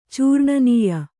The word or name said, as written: ಚೂರ್ಣನೀಯ